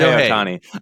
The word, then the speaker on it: Shohei Ohtani: Shohei, is that how you say it? Shohei, Shohei, Sho, Shohei, Shohei, Shohei. Ohtani. I even tried to spell it out phonetically, and I still have a trouble.